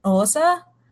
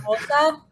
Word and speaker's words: Xhosa: So it's spelled X-H-O-S-A, but there's a click in the beginning. Then that's the name of it's like Osa or something like that.